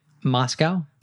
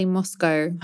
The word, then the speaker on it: Moscow: So I'd say Moscow. – Yeah, and I would say Moscow.